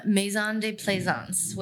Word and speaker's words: Maison de Plaisance: Well, really, I named my brand Maison de Plaisance, which that is how you pronounce it. I thought it looked phonetic, but maybe it doesn't.